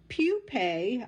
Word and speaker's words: pupae: That mulch is also perfect habitat for invertebrates that birds eat, including the pupae. I had to look this word up. It's P-U-P-A-E. I did not know that word. Pupae, it's pronounced. I had to look up the pronunciation.